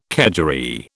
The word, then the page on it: kedgeree: British English